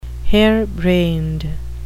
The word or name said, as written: harebrained